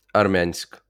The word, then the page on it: Армянськ: [ɐrˈmʲɑnʲsʲk]; Ukrainian